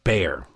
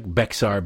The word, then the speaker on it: Bexar: So now we come to this this sheriff down there, and somebody emailed me yesterday and said that Bexar is pronounced bear. It's like pronounced bear. I like Bexar better. It sounds like the Kmart version of Pixar.